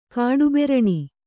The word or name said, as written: ಕಾಡುಬೆರಣಿ